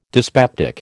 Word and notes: dyspeptic: American English

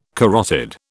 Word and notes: carotid: British English

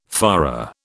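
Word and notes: führer: British English

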